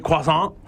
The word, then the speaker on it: croissant: Sorry, can you say it properly, please? – Croissant.